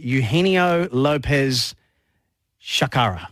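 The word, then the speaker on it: Eugenio López-Chacarra: Now, this is a big story in European golf because, and you'll help me with the pronunciation because you're such a well-travelled, worldly gentleman. but I'll go with Eugenio Lopez Chacara. – Perfect. – Yeah. Is that right? – Yeah, I think that's pretty good.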